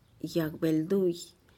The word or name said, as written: yag belduih